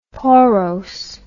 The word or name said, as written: porose